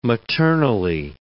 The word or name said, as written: maternally